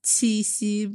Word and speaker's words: Qixi: Qixi. Am I pronouncing that correct? – Very good. I mean, Thank you. You want to pronounce it even more Chinese, it's Qixi, but it's, I know that. – Qixi.